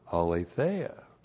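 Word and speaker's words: Aletheia (ἀλήθεια): Alethenos is an adjective that is related to the noun, you know the noun, aletheia. A-L, long E-T-H-E-I-A. Aletheia. And aletheia is the noun that means absolute truth. So point two, aletheia is the noun that means absolute truth.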